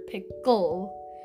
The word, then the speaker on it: Pickle: Actually, his name was Dr. Pickle, with the accent on the second syllable.